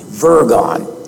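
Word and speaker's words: wyrgan: The derivation of the word worry is from the Anglo-Saxon word virgon, W-R-Y-G-O-N. The W is spelled as a V, virgon, which means to strangle.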